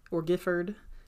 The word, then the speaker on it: Guildford: a man named Lord Gifford Dudley. I think I'm going to say Gifford because that sounds better, but it's spelled Guildford, just so you know.